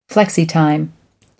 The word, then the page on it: flexitime: American English